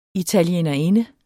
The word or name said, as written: italienerinde